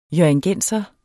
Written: hjørringenser

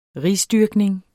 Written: risdyrkning

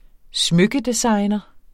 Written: smykkedesigner

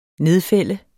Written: nedfælde

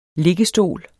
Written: liggestol

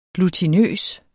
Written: Limagtig

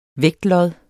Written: vægtlod

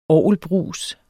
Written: orgelbrus

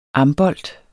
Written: ambolt

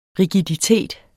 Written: rigiditet

